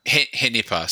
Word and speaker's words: Henipa: Henipa. So it's a combination of Hendra and Nipa, which are the two viruses that were previously described in that genus.